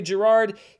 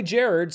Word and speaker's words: Gerard: Some people say Gerard. He says that it was originally Jared, so that's what I'm going to say here on the show.